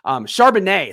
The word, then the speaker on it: Charbonnet: Charbonnet, excuse me. I said it the wrong way, but because you spelled it that way, it threw me off. Charbonnet, it's supposed to be pronounced like the wine.